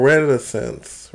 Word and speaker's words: reticence: Retinence? – No, because there's no N there. – R-E-T-I-C-E.